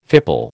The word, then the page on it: fipple: American English